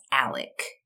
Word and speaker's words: Alex: Both are Alex with an X. – Oh, okay. – But it's pronounced Alec.